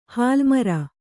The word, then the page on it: ಹಲ್ಲುಮುರಿ: hallu muri